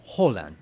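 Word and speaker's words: Haaland: Ay, sí, ¿cómo se pronuncia? – Es Holland. – Holland. – Holland, sí.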